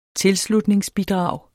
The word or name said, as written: tilslutningsbidrag